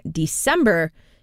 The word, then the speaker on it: DSEMBR: dreamy indie songs that they write and produce under the name December. It's spelled D-S-E-M-B-R.